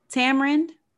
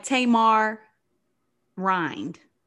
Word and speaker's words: tamarind: Tamarind. It's like tamar rind. That's how you spell it.